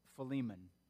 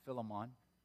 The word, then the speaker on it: Philemon: It is the book, the letter of Philemon, sometimes pronounced Philemon.